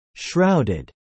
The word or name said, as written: shrouded